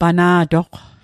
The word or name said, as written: pana'toq